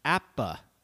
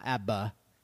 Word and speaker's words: APBA: "My favorite tabletop baseball game, however, is APBA, pronounced APBA." Not ABBA, APBA.